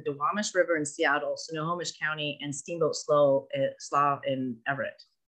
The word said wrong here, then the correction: Slough: Slough, there we go. I was like, I know I said that wrong. Because it doesn't, it's not spelled. – It's a weird spelling.